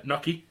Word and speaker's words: gnocchi: Anyway, he cooked a Manx lamb with lamb shoe, potato gnocchi. Right, I've been outside trying to learn that word, and I can't pronounce gnocchi.